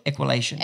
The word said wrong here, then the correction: Equilution: Did I say it wrong? Equilution. – Yeah.